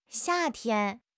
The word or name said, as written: xia4 tian1